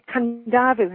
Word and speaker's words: Kadavu: There's an island called Kandavu, and that's spelled Kadavu.